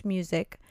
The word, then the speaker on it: Muzik: His name was Bruce Music. And he talked about these ACE markers. – That's a cool name. – Yeah. You know, it's funny, it's spelled M-U-Z-I-K, but it's still pronounced music.